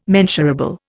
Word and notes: mensurable: Indian

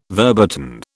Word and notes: verboten: British English

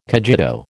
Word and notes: cogito: American English

